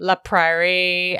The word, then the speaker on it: La Prairie: Like, she uses high-end beauty products like La Prairie and La Mer.